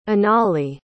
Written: inali

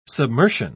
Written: submersion

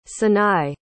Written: sanai

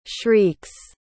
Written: shrieks

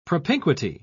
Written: propinquity

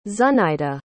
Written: zuhnyda

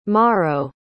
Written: Mauro

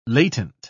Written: latent

